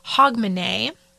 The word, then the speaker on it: Hogmanay: So in Scotland, December 31st is a day known as Hogmanay.